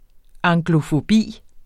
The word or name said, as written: anglofobi